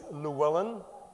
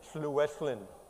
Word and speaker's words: Llewellyn: The English way is Llewellyn. I was actually born in Wales, and it's unpronounceable in Welsh. It's Llewellyn. And that sound does not exist in English or in most languages of the world, so very difficult for people to pronounce.